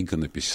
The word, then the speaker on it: иконопись: Потому что иконопись с ударением на первый слог.